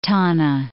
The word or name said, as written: Tana, Lake